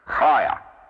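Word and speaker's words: Chaya (חיה): Do note that CH in Hebrew is always pronounced in a guttural way. Chaya. It's rather similar to the way the Scots pronounce that same pair of letters, as in Loch, L-O-C-H, meaning a lake.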